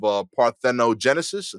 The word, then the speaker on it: parthenogenesis: It's a rare case of parthenogenesis. I think I'm saying it right. – Yeah.